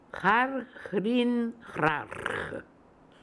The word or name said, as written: Harhrinhrarh